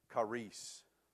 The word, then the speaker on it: charis: Now, the Greek word that's behind that is this Greek word that's pronounced charis. C-H-A-R-I-S.